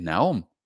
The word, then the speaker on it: Nahom: Nene m dicen los mormones, es Naom porque Naom se escribe N-A-H-O-M.